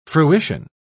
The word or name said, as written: fruition